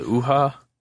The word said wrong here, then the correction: Unha: UNHA 2, yeah, UNHA.